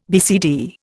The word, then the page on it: BCD: Indian